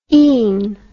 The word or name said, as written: ein